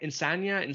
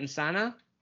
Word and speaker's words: Insana: Am I saying it right? Insania? Insana? – I think it's Insana. If there was a tilde on the end, maybe it would be Insania, but I think it is Insana, yeah.